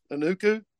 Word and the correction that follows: Ukwu: Isaac Ukwu. Yeah.